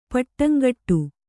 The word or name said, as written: ಪಟ್ಟಂಗಟ್ಟು